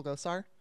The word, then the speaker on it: Gosar: Is it Gosar? Paul Gosar? – Uh-huh, that's his name.